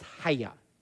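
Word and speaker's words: haya (חיה): Basically, that word comes from the Hebrew word, in Hebrew it's pronounced haya. It's like C-H-A-Y-A-H. It looks like chaya, but it's haya in Hebrew.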